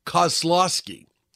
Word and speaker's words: Kozlowski: 1990, Paul Hogan and Linda Kolas. I was never able to pronounce her name right. It's Kozlowski, wed in Byron Bay, Eastern Australia.